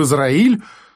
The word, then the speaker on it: Израиль: Хоть в Германию, хоть в Израиль. Слово «Израиль» моя собеседница почему-то выговаривала неумело, с ударением на третьем слоге, но с заметным стыдливым удовольствием.